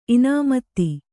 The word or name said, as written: ಇನಾಮತಿ